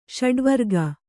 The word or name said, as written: ಷಡುವರ್ಗ